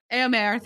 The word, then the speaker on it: Eomer: Eowyn and Eomer, how do we say his name? Eomer, thank you.